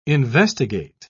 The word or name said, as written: investigate